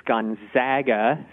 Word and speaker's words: Gonzaga: So yeah, we tend to hear Gonzaga a lot, but we want folks to know it's Gonzaga. So think of zag as in bag. And that's the right pronunciation.